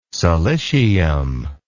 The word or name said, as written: silicium